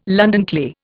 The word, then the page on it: London clay: Indian